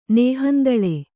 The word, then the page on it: ನೇಹಂದಳೆ: kannada